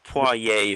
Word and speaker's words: Poirier: Poirier. – I think it's like Poirier if you want to really go French with it.